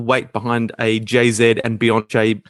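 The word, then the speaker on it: Beyoncé: It's pronounced Beyonce. – Beyonce.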